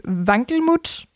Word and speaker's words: Wankelmut: not sure if I'm pronouncing this the right way, but Vankelmut. Yeah. Yeah. Okay. With Wankelmut, uh, and I want to know about that single.